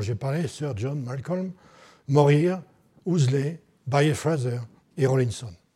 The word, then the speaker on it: Morier: Et parmi les trois principaux Anglais qui ont parlé de la Perse, Morier, Ouseley et Fraser, ils mettent en avant le premier, alors je vais le prononcer à la française, hein, Morier,